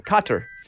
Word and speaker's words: Kotter: Welcome back, Cotta. – Cotter? – You never say that right. Say it. Spell it for me. – Cotter. K-O-T-T-E-R.